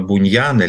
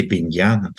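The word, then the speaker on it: Bunyan: Я, например, там вот во вторник за одну лекцию пытался рассказать про Джона Буньяна, или Беньяна, тут по-разному его произносят. – Не английский Буньян, а по-современному Беньян.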